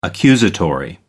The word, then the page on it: accusatory: American English